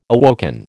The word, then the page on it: awoken: American English